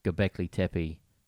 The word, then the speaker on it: Göbekli Tepe: Like we're finding new stars and new galaxies and we've just found Göbekli Tepe.